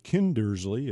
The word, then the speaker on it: Kindersley: Let me get it right. Kindersley is the proper way to pronounce it.